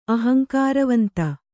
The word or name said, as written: ಅಹಂಕಾರವಂತ